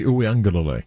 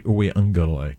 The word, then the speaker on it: Uiagalelei: DJ Uiagalelei. – Oh, there's an extra G in there. Uiagalelei. Say it again. – DJ Uiagalelei. There's an extra syllable in there that you got to get.